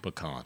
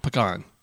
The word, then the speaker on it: pecan: And, of course, the way it's said in Texas is the correct way. – Pecan. – Pecan. – Yeah. Yeah. And then if you say, how do you say T-O-M-A-T-O? – Tomato.